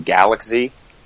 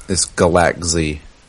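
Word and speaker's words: Galak-Z: I don't think it's Galax-Z. Isn't it just Galaxy? – No, it's Galax-Z. – It's not just Galaxy? Because it phonetically spells Galaxy.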